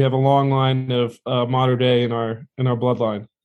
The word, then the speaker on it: Mater Dei: And I've heard several different ways of saying your high school. So I'll let you tell us how the proper pronunciation for your high school. – Yeah, so I went to Mater Dei High School.